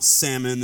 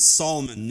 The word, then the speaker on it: Solomon: Solomon, it's not Salmon, it's Solomon, Nashon, and Boaz, and I mean, that one's not that hard, but there's others.